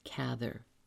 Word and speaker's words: Cather: I said Cather then, but since then, everywhere I turn, it is pronounced Cather. So I give in.